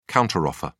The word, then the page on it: counter offer: British English